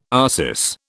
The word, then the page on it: arsis: British English